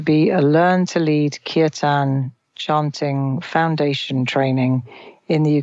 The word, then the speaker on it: Kirtan: Talk to me for a moment about the Learn to Lead Kirtan, I think you pronounce it. – Kirtan.